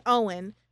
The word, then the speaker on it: Eoin: Like, say you come over and your name's Owen, which is spelled E-O-I-N.